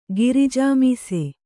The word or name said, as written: ಗಿರಿಜಾಮೀಸೆ